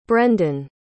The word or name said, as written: brendan